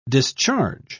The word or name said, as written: discharge